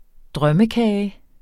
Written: drømmekage

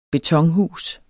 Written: betonhus

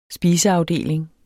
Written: spiseafdeling

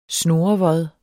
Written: snurrevod

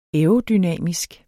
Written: aerodynamisk

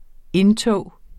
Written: indtog

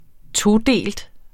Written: todelt

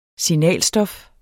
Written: SIGNALSTOF

